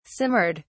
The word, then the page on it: simmered: British English